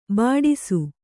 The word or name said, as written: ಬಡಿಸ